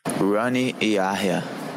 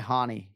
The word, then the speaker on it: Rani Yaya: Rani Yaya. Rani Yaya. – He does not go by Hani. He has Americanized it. He says Rani Yaya.